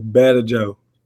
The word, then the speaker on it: Badejo: My last name is Badejo.